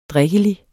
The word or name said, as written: drikkelig